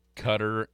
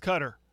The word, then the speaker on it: Qatar: It's Qatar and I can't remember who else.